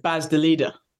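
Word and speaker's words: Bas de Leede: and Chev's compatriot Baz de Leader. Is that the right pronunciation? – That's pretty good.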